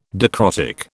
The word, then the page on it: dicrotic: British English